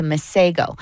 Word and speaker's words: Masego: However, in South Africa, the name is actually pronounced Masepo. But he didn't want to be, as he said, a bit of a culture vulture. So he changed the pronunciation to Masego.